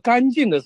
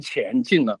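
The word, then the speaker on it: 乾淨: 他们你看现在很多网上的那些视频现在都很多用 AI 来做了，他们都用所谓的正体字了，他在念到那个干净的时候他就念成前进了，因为那个干和乾坤的乾哈，但是他们就是大陆的人搞不懂的，就是他学简体字残体字的人，他们是完全搞不懂这一部分的，所以他们自己都没有办法把它纠正过来，这个反而是一个方便的这个识别。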